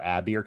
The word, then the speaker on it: Abir: I mean, I just call it Abir because it's A-B-I-R.